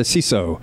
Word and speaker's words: CISO: They call it a CISO. – A CISO. – Yes, they love to call it, they love pronouncing acronyms.